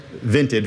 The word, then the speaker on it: Vinted: Vinted, V-I-N-T-E-D. – Okay. – Vinted. – Like vented with an I.